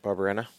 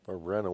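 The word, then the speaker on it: Barberena: I'm going to butcher his name. Barbarena, right? – That sounds right to me.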